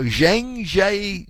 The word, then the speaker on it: Zhangjiajie: So, Zhangjiajie. Three separate words, this community, this city in Hunan province.